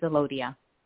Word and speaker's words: Alodia: Am I saying that right? Alodia? – Yes, it's Alodia.